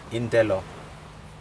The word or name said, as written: intelo